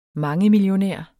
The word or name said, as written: mangemillionær